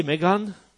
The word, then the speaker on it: Meghan: Nun ist aber seine, wie heißt sie, Meghan, aha, so wird sie ausgesprochen, ja. So,